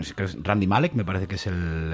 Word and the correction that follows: Rami Malek: no sé si lo he pronunciado mal. – Rami Malek, sí.